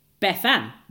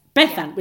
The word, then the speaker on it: Bethan: Our elder daughter is called Bethan, which we and his family pronounce Bethan with the stress. Bethan, with the stress on the first syllable.